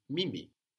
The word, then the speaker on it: mimi: So ni shows I or me, but the word for I or me is mimi. M-I-M-I. Like English me, you know, but spelled with an I.